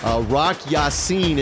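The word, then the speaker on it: Rock Ya-Sin: Here, the defensive back, Rock Yassin is how you say his name, grabbed Mike Evans around the waist before the pass was being thrown.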